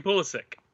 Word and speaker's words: Pulisic: Christian Pulisic. However you want to say it? – Yeah, Christian Pulisic. – Pulisic. – There's no H.